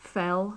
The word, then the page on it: fell: English